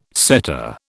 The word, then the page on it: setter: British English